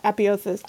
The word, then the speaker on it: apotheosis: Apotheosis. There you go. I always miss a syllable. – There's okay, an e so in there.